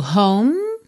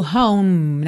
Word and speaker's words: home: Então nós temos go home, get ahead. Olha, go home, nada de falar home, ok? Esse E final não é pronunciado.